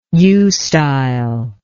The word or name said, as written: eustyle